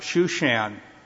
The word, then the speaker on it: Shushan: In the Hebrew, it's pronounced Shushan.